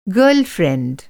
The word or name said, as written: गर्लफ्रेंड